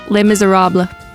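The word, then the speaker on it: Les Misérables: Les Miserables.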